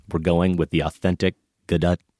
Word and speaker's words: Gadot: We are going with Gadot because we heard her say that on Jimmy Kimmel. – Confirmed. – We can show you the link. That's how she pronounces her name.